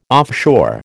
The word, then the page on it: offshore: American English